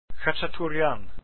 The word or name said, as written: khachaturian